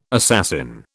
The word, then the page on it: assassin: British English